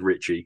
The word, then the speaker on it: Ricci: I've always said Ricci.